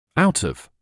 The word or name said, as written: out of